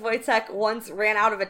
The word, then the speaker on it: Wojtek: Spelled with a W, pronounced with a V, because... – Well, it's Polish. – many of the lower officers take care of Wojtek and...